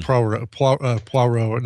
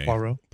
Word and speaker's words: Poirot: Like Poirot. Not Ross Perot.